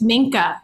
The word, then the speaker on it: Minka: And mine, I'm going to tell everyone because it's Minka. That's her name. – Ooh, how do you spell it? M-I-K-A? M-I-N-K-A.